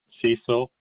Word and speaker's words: Cecil: Cecil, C-E-C-I-L. Morphew, M-O-R-P-H-E-W. – Yeah, that was M-O-R-P-H-E-W? – Yes.